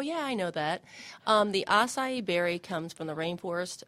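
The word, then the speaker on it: açaí: And am I saying that correctly? – You are saying it correctly. It's pronounced acai. Most people pronounce it acai.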